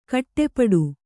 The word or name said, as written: ಖಟಿಪಿಟಿ